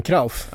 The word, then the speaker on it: Cruyff: Johan Krauf. – Ja, Clough. Det var det jag tänkte. – Det uttalas Krauf. Jag frågar vilken holländare som helst. Krauf. Eller, nej, inte Krauf.